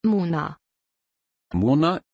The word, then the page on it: Muna: German